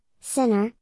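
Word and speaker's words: Jannik Sinner: With 212,192 views on Tuesday, July 5, 2022, our article of the day is Yannick Sinner. Yannick Sinner, Italian pronunciation, Sinner, born August 16, 2001, is an Italian professional tennis player.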